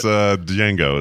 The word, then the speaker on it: Django: No, it's Django is that one. It's Django. – Oh, Django. Oh, of course, the D is silent.